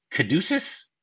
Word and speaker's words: caduceus: And he has what's known as a caduceus. Am I pronouncing? I don't know if I'm pronouncing that right. – Oh, you are. You are pronouncing that properly.